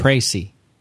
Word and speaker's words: précis: Like a précis. Oh, I love that word. And you said it right, too.